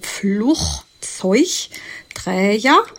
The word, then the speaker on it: Flugzeugträger: Kleiner Tipp. Jedes G anders, aber kein G als G. Da habt ihr geraten, das erste rachig, das zweite weich, also Flugzeugträger.